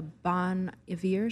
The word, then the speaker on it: Bon Iver: And then I think we went to a Bon Iver show.